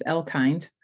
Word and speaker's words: Elkind: Suzanne, did I say your last name right? I've only known you for like 3 years, but did I say your last name right? – It's okay. It's Elkind.